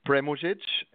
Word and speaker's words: Premuzic: The pronunciation is Premuzic, and it means it's short for Prvi Mužić, which is the first husband.